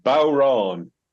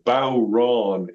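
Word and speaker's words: bodhrán: So even though we have a DH there, it sounds like a W instead. So Bowron, it's actually what's that's what it so, sounds like.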